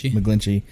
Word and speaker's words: McGlinchey: McGlinchey.